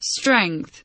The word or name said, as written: strength